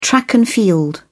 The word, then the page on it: track and field: British English